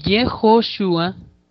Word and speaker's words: Yehoshua: Por eso es que en realidad ahora nosotros pronunciamos Yeshua en arameo y Yehoshua en hebreo, igual que el nombre de Josué, que le apareció aquí arriba.